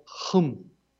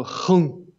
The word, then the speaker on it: hum: We had B-H-R-U-M, pronounced druv. And then we have H-R-I-H, which, again, is just like it sounds. Then we have hum, or hung, which is spelled H-U-M, but can be pronounced hung, right? Hum, hum. And then we have yo, which is spelled B-H-Y-O, but can be pronounced J-O.